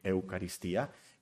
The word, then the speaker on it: Eucaristía: Hay otras religiones que a la Santa Cena le llaman la Eucaristía, ¿lo pronuncié bien? Acento en la I, Eucaristía.